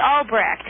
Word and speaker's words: Albrecht: It's Albrecht. It's A-L-B-R-E-C-H-T with a silent H, Albrecht.